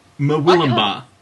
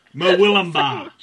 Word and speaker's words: Murwillumbah: Like Mer, like murder. Hui, Will, like William. I can't pronounce this name. Ba, as in ba, get the name right. Mwilumba.